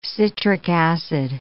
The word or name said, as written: citric acid